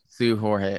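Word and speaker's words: Seu Jorge: Sue Jorge.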